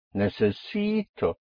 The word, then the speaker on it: necesito: Here we have four syllables. Necesito. You have a vowel at the end, so you push down on the syllable before last, on the penultimate syllable, which makes it necessi. And then you come up on O.